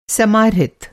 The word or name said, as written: समाहृत